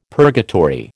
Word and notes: purgatory: American English

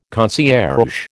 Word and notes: concierge: American English

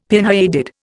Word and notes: pinheaded: Indian

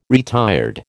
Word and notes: retired: American English

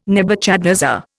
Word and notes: Nebuchadnezzar: Indian

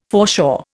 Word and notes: foreshore: Indian

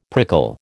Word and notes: prickle: American English

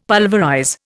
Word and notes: pulverize: Indian

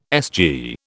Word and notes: SG: British English

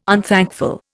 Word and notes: unthankful: Indian

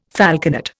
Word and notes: falconet: Indian

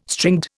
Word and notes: stringed: Indian

